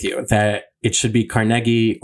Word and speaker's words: Carnegie: But Carnegie is correct. And I think in the original Scottish, Carnegie was what he grew up pronouncing.